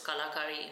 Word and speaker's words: Kalakari: So supply chain marketing, finance, and everything else is something which is in-house to my brand, which is Kalakari India.